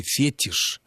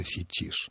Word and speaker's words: фетиш: если бы мы услышали фетиш вместо фетиш, то это можно было, здесь еще можно было бы рассуждать о том, не является ли это таким вот ложным акцентным англизмом. А когда эти слова произносятся так, как они должны, феномен и фетиш, то здесь такой разговор просто ни к чему.